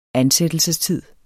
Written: ansættelsestid

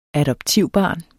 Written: adoptivbarn

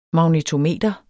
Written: magnetometer

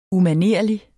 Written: UMANERLIG

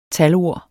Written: talord

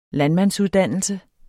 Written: landmandsuddannelse

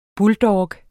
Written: buldog

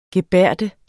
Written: gebærde

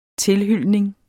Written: tilhylning

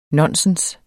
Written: nonsens

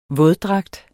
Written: våddragt

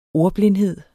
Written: ordblindhed

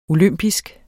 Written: olympisk

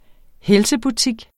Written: helsebutik

